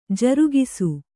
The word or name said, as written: ಜರಗಿಸು